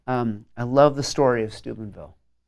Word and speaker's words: Steubenville: And so the first is, I'm nervous about saying it correctly, Steubenville in Ohio.